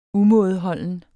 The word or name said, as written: umådeholden